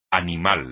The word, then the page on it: animal: espagnol